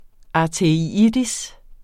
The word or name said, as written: arteritis